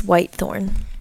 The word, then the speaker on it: Whitethorn: Also look at it here, how it's spelt. W-H-I-T-E-T-H. – You're right. It's Whitethorn.